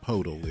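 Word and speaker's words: Pöltl: Jakob Poeltl. – And or Jakob Poeltl, if you don't care about pronunciation.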